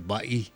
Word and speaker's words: Bay: Yung bigkas dyan, mga ka-RJ Patrol, Bai, hindi Bay, Laguna de Bay. Sa akin kayo makinig. Joke. Yung po ang tamang bigkas dyan.